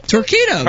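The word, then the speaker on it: Torqeedo: Torquedo? – Torquedo. That's right. You said it right.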